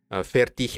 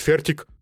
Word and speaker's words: fertig: Fertig, э, а, да, даже произношение, то есть эм, по правилам немецкого языка окончание иг читается как их, то есть fertig. – Mm-hmm. Австрии сказали, зачем? Мы будем говорить фертик.